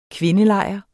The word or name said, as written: kvindelejr